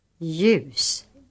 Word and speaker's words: ljus: The letter J is sometimes preceded by another consonant in the writing system. In such cases, the other letter is always silent. Examples. – Jude. Djur. Hjälp. Ljus.